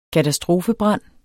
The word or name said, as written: katastrofebrand